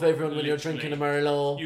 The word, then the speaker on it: Merlot: It's because the tea is silent in Merlot.